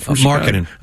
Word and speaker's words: Markkanen: and Lauri Markkanen. Am I saying that right? – Markkanen. – Okay, well, that's the part of someone who didn't get a lot of screen time this year because he was on the Bulls.